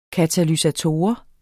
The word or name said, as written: katalysator